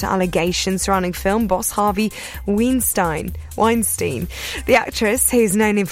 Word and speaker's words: Weinstein: Weinstein. – No, the way I spell it is wine, like the drink. – Yeah, and then Steen, but yeah.